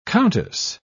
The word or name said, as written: countess